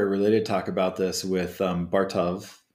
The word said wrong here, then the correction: Bartosz: Yeah, Bartosz.